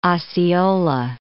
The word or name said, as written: Osceola